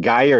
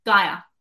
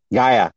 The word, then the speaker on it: Gaia: Is it Gaia or Gia? – Gaia. – Gaia.